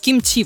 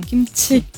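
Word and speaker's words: Кимчи (김치): Вот, например, в том же кимчи вообще оно произносится с легким придыханием. Кимчи.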